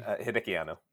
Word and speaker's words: Hideki Anno: Hideki Anno.